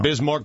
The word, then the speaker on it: Bismarck: I don't think you're pronouncing that correctly. – It's not Bismarck? – No. – Bismarck Biyombo. Let me check it out then.